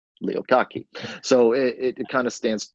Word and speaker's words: Kottke: I think that's how you say it, Kottke. – Yeah.